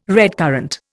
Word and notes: redcurrant: Indian

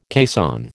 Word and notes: caisson: American English

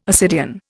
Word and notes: ascidian: Indian

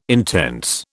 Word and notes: intense: British English

